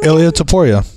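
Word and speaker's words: Topuria: Um, he got into a little tiff today with Ilya how do you say his last name? – Ilya Taporia.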